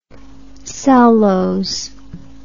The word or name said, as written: cellose